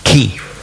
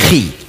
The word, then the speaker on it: Chi: Chi, das hat man eigentlich ausgesprochen, Ki. Nicht Schweizerdeutsch, Chi. Also nicht Chuchichastli in dieser Art, sondern in Ki.